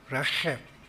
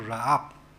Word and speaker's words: Rahab: It is a throaty pronunciation, just like that in Hebrew, Rahab, okay? So Matthew most likely used the pronunciation to write the word rather than using the Septuagint, which is a softer pronunciation called Ra'ab.